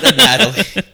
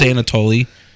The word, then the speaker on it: Anatoly: Not a Natalie. During our initial read-through, he just kept saying a Natalie. – On purpose. I know it's Anatoly.